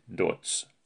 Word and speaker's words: Dodds: Hans namn stavas Dodds med två D men uttalas med T, Dodds.